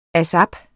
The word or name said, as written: ASAP